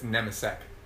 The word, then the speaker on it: Nemecek: It's Nemecek. – Nemecek? – Like it's S-E... Like it would be S-E-K, but it's just...